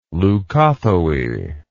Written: leucothoe

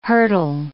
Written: hurdle

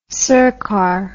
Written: sirkar

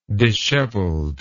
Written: disheveled

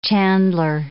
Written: chandler

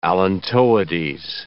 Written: allantoides

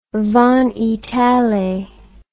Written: Van Itallie

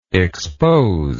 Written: exposer

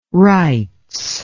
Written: rights

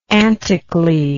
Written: anticly

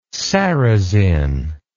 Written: sarrazin

